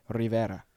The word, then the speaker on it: Rivera: Rivera.